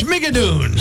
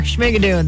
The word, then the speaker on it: Schmigadoon: Mart's Trailways is heading to New York City Sunday for, I don't even know how to pronounce it. – Schmigadoon. – Sure, Schmigadoon.